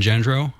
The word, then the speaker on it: Gendreau: If you search my name, Evan Gendreau, on Facebook or Twitter. – How do they spell that? – G-E-N-D-R-E-A-U.